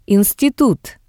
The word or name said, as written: institut